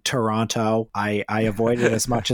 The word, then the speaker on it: Toronto: By the way, my wife, a Canadian, told me the dead giveaway that you're a foreigner is if you say Toronto. Mm. The correct way to say it, if you're Canadian, is Toronto. They kind of muffle that T into a D.